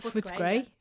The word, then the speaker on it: Footscray: So the second story is about a primary school in Melbourne, Footscray. Did I pronounce it right? Footscray.